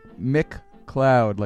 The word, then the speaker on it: McLeod: After that, we heard El Nutto, the title track from the album El Nutto by Terry Gibbs with Alice McLeod, this time spelled like McLeod, like the TV show with Dennis Weaver. Except that was many years before. MCL, like Mick Cloud, like a cloud in the sky.